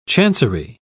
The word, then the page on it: chancery: British English